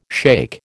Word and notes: shake: American English

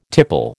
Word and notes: tipple: American English

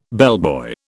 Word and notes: bellboy: British English

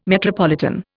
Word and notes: metropolitan: Indian

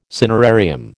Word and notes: cinerarium: American English

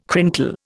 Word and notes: crinkle: Indian

